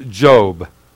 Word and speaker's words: Job: And then in Job 23:10, Job is spelled Job, pronounced Job.